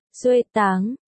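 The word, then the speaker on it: xuê táng (学堂): Hồi nãy khi Vũ Văn Tuần nói với Thanh Ti, ảnh dung từ xuê xiao, học giáo, phát âm, xuê xiao, còn Thanh Ti dung từ xuê tang, học đường, phát âm là, xuê táng, cả hai cái đều có nghĩa là trường học, nhưng mà hồi xưa người ta xài cách nói của Thanh Ti.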